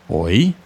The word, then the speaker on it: hoy: So, in Spanish, to say today, we would say hoy. It's pronounced just like how when us Brits try to grab someone's attention by saying hoy. Oi mate, oi. Oi is today.